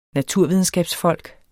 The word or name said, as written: naturvidenskabsfolk